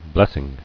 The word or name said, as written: Blessing